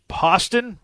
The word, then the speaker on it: Poston: We have JT Poston, rhymes with Boston, over Putnam.